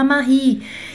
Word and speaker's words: Marie: 10, 15, não importa, porque você está aprendendo, você está aprendendo como é que se fala Marie. E você não está aprendendo só como se fala Marie, você está aprendendo também Marie, que se escreve M-A-R-I-E, porque não falo E, mas você ouviu uma I.